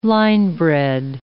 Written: linebred

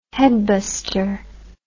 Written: headbuster

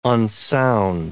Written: unsound

